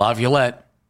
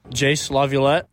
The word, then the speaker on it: LaViolette: Now, we have to say it correctly. – Mm-hmm. – LaViolette, because Chase pronounced it for us, and we know exactly how he pronounces his name.